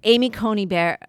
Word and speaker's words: Amy Coney Barrett: So Amy Coney Barrett, am I saying her name right? – Yeah.